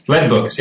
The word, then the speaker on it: Lead Books: Am I pronouncing that? Is it Lead Books, right? – Lead Books, yeah.